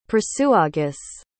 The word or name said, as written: prasutagus